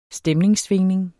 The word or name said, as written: stemningssvingning